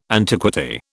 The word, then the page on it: antiquity: British English